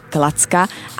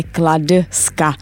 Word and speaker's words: Kladska: Takže za doby ne krále Klacka, ale krále Kladska.